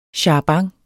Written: charabanc

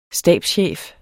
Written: stabschef

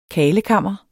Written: KARLEKAMMER

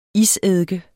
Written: iseddike

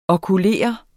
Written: okulere

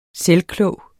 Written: selvklog

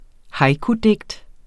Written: haikudigt

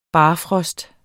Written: barfrost

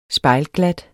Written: spejlglat

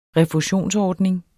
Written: refusionsordning

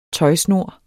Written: tøjsnor

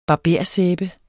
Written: barbersæbe

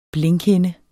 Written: blinkhinde

